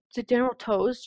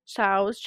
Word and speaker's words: Tso: Like General Tso's chicken? Tso's chicken? – Tso, yeah. – Yeah, that's how you say it.